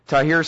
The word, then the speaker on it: Tahrir: Well, now, but you're saying, you know, the million people out there in Tahrir Square, is that how it's pronounced? – Yes.